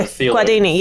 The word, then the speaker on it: Guardini: And I believe it was a video as well that he looks at, is it Gaudini? – Yeah. Guardini, yeah.